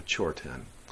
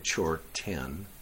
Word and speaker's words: chorten: And the etymology of that is chö ten. Chö means the same thing as dharma in Tibetan. And ten, which is spelled R-T-E-N in Tibetan. So that's how you get chorten, Americanized Tibetan, sorry. But that's the way you get it. Ten, R-T-E-N, means a holder, a place where something is held.